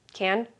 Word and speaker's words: Cannes: Cannes? Mm-hmm. Oh. I've been saying that wrong my whole life. – Well, I mean, yeah, it looks... – the French. – The French. – They just don't pronounce half the letters in their words.